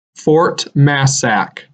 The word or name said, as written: Fort Massac